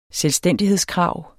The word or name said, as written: selvstændighedskrav